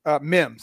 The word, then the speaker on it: Mims: How do you say his last name? – Mims.